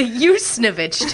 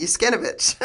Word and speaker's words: Yusnovich: Oh, the Yusnovich Tones. – It's hard to spell, so I always pronounced it wrong, which is I always said King Yuskinovich.